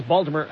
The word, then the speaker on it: Baltimore: And Delaine, Sarah's friend, who is from Baltimore, she pulled me aside and she said, no, you got to replace the T with a D. And then I watched many episodes of Homicide: Life on the Street, and I realized that is in fact how you say it.